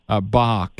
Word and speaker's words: Bok: Tell me how that last name, Bart Bok, is spelled. – B-O-K. – B-O-K? – Yes. Bok. – Huh. Bok. – Yeah. It's not like the musician. – Okay. – Not like the composer.